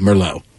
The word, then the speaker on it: Merlot: Were you like Merlot? – Well, I guess you're right, Merlot. Yeah, it's spelt Merlot. – Yes, it is. Like escargots. Going to have some Marlott and escargots. They rhyme.